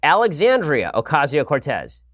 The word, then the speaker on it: Alexandria Ocasio-Cortez: Alrighty, so today we begin, again, with Alexandria Ocasio-Cortez.